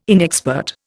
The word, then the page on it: inexpert: Indian